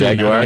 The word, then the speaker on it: Jaguar: And you pronounce it Jaguar too. – Jaguar. Yep. Yeah, you have to pronounce the U's.